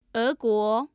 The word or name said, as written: eguo